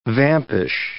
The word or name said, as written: vampish